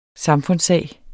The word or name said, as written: samfundssag